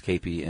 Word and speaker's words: kepi: Yeah, spelled K-E-P-I, pronounced capey, and they still wear those.